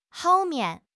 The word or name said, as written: hou4 mian5